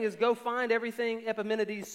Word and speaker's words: Epimenides: Paul does that with Epimenides. And that's how you pronounce it. Don't question that. Epimenides.